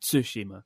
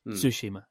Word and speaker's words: Tsushima: Someone asking how to pronounce Tsushima, and it's kind of like Tsushima. Right, there's a subtle difference between Tsushima and Tsushima. Just enunciate the T. Tsushima, and that's the key.